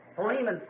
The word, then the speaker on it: Philemon: So, Philemon, someone said back there, which I think is really the correct pronunciation of it.